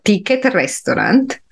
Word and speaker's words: Ticket Restaurant: Yeah, that's how you have to pronounce it. So ticket restaurant.